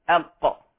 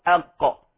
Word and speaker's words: أق: مثلا حرف قاف اسمه قاف، ولكنه ينطق إذا وضعنا قبله همزة أق أو أق.